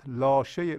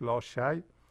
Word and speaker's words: لاشی: این خیالت لاشه لاشی تلفظ صحیحش لاشی